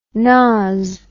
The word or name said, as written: nause